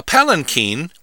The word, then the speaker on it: palanquin: A palanquin, which of course rhymes with Martin Sheen, is a covered chair or litter on poles, sometimes called a sedan chair, carried by four or more bearers.